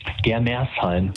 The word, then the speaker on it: Germersheim: Heißt das richtig, Germersheim? Habe ich es richtig gesagt? – Germersheim.